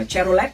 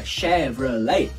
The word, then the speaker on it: Chevrolet: đừng đọc là Chevrolet các bạn hãy đọc là Chevrolet Chevrolet không đọc là let đọc là lay